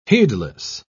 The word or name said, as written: heedless